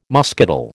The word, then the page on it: muscadel: American English